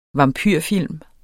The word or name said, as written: vampyrfilm